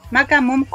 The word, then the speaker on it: Maca Mom Coach: Mira, en Instagram estoy como Maca Mom Coach, que se escribe M-A-C-A-M-O-M-C-O-A-C-H. Macamumcoach.